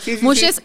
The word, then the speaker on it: Muxhe: Mushes se escribe M-U-X-H-E.